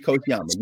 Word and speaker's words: Kochiyama: And so when I saw Yuri Kobayashi, is that how you pronounce that? – Koshiyama. Yuri Koshiyama. Yes, she married Bill Koshiyama, who was a veteran.